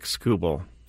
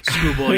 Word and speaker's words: Skubal: Someone on the chat has a good point about pronouncing names wrong. It's Tarek Skubal. – Oh, see, there you go. – Yes. – I was trying to make him a foreign pitcher. Tarek Skubal, yeah.